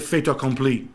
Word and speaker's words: fait accompli: fait accompli.